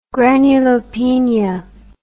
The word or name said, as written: granulopenia